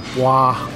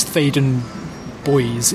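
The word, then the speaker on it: Theydon Bois: People often mispronounce it as, or don't know whether it's Boys or Bois or Thaden or Thaden. I think it's Thaden Boys is the correct way to do it.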